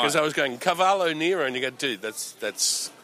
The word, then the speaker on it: cavallo nero: And it's, if I say this wrong, you have to be careful how you pronounce this, because if I say it wrong, you used to give me a hard time, because you say, that's a horse. – Yes. – Cavallo. – No, cavallo nero.